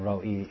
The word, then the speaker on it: Roi: And I will point out to her that the O vowel in Roi is a kametz chatuf. It is a short O, not a long O, and that's why it's not a participle with a personal suffix.